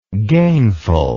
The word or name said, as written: gainfulness